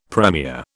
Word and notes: premier: British English

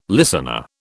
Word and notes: listener: British English

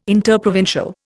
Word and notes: interprovincial: Indian

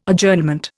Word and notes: Adjournment: Indian

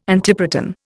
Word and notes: antiproton: Indian

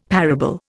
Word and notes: parable: Indian

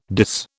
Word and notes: dis: British English

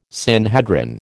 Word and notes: Sanhedrin: American English

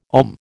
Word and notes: ogham: American English

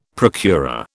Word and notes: procurer: British English

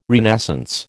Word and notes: renascence: American English

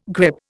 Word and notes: grippe: Indian

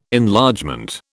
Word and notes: Enlargement: British English

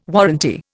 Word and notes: warranty: Indian

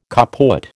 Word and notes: kaput: American English

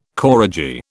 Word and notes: koradji: British English

